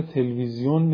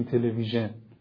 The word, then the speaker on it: تلویزیون: یا ما به تلویزیون نمیگیم تلویژن، انگلیس ها میگن تلویژن، چون فرانسوی ها و اینا بودن که اولین بار بیشتر ما باهاشون مواجهه داشتیم، ما به تلفظ اونها میگیم تلویزیون.